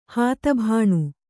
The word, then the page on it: ಹಾತಭಾಣು: kannada